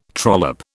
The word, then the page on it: trollop: British English